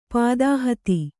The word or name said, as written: ಪದಹತಿ